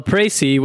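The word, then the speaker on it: précis: Well, a Pracy, which I looked up, is the correct pronunciation of that word, and it means a summary or abstract of a text or speech.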